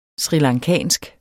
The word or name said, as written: srilankansk